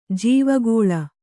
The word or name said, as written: ಜೀವಕಾಲ